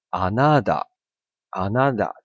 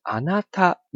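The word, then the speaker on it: あなた: 如果你真的是念浊音的话，你会变成是 anada anada，这样就是你念错了嘛？哦，所以你念成 anata，你只要你是用喉咙发出它的声音的话，那就是没错的。那你会说，那我听起来为什么那么像哒呢？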